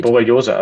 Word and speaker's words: Borreliose: Borreliose. Also das ist ein Borreliose, genau.